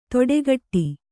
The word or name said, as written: ತೊಡೆಗಟ್ಟಿ